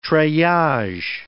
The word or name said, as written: treillage